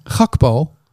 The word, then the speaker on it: Gakpo: Gakpo.